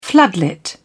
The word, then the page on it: floodlit: British English